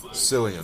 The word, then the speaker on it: Cillian: It's Cillian. – Cillian. And so for some people, it can be Killian. It's just how you choose to pronounce it.